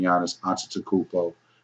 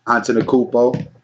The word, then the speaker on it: Giannis Antetokounmpo: Shout out to motherfucking Giannis Antetokounmpo. Please, that's how you say his name, people. Antetokounmpo. That's how you say his name for all you people that don't know how to say that shit.